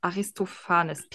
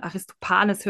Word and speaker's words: Aristophanes: Aber ich glaube, man spricht es so aus. Aristophanes. PH würde ich als F aussprechen. Aristophanes hört sich nämlich komisch an.